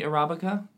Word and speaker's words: Arabica: And because of that, it also has more caffeine than the Arabica.